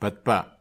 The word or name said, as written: patpa